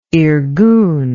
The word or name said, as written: irgun